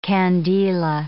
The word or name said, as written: candela